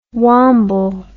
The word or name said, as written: womble